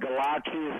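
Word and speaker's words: Gallace: So the name of the restaurant is Villa Galacci, which means House of Galacci. That's my last name. – Okay. – And Galacci is spelled like Wallace, but with a G.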